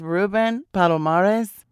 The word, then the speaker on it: Ruben Palomares: It is Ruben Palomares.